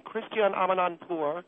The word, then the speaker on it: Christiane Amanpour: I came on your program to praise Christiane Amanpour, which, by the way, that is her name, Christiane Amanpour. You people have mispronounced it.